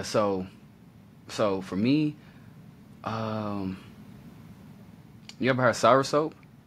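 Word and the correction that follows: soursop: Sop, if I'm saying that right. – Yes, Sarasop. – Sop, yeah, I said soap. Sour sop.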